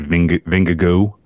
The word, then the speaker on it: Vingegaard: No, I think they tend to kind of just kind of brush over the last part and they say Vingegaard.